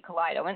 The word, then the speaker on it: Kaleido: And you know, to be honest, Christine, I was just dying because when I was reading your bio, at first I'm like, how do you say this name? Is it Kaleido? Kaleido? And then it was so great because at the end you had your, you know, the quote of Kaleidoscope and what it means. And I was like, oh, okay, there you go. Perfect. I got the pronunciation correct.